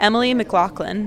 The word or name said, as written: Emily McLaughlin